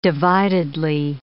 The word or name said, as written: dividedly